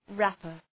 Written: rapper

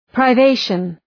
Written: privation

